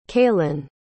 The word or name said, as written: cailan